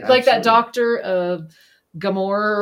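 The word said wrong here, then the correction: Gabor Máté: Oh, he's Gabor Mate.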